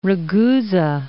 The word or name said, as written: Ragusa